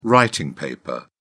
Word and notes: writing paper: British English

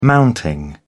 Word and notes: mounting: British English

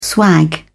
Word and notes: swag: British English

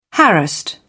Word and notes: harassed: British English

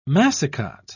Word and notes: massicot: British English

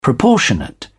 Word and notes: proportionate: British English

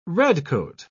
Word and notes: redcoat: British English

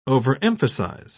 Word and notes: overemphasise: British English

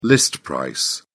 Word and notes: list price: British English

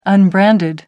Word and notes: unbranded: American English

